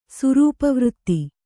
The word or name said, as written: ಸುರೂಪವೃತ್ತಿ